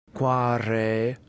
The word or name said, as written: quare